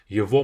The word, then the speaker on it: его: It can be его кружка, his cup. Его мама, his mom, etc. Его. Even though it's spelled as ЕГО, we don't say его, we say его.